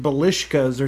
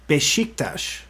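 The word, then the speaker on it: Beşiktaş: You said Belishkas or something like that. It's actually B-E-S-I-K-T-A-S, pronounced Besiktas. – Besiktas. – Besiktas. – So I was wrong. I threw an L in there.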